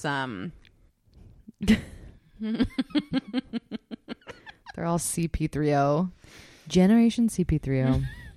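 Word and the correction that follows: C-3PO: I think it's C3PO. – Oh, damn, I always say it wrong.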